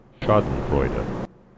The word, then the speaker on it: Schadenfreude: And joy in others' suffering is the correct word, and we definitely need this pronunciation, so listen up. – Schadenfreude. – Schadenfreude. That's not an English word.